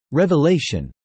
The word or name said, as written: revelation